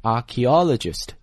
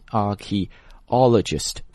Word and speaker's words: archaeologist: 我再读一遍哈 ,archaeologist, 大家仔细看一下哪个字母不发音啊 ,archae, 是吧，这个 h 音是不发的，然后 ae 作为一个字母的组合在这里发 e 这个音 ,archaeologist,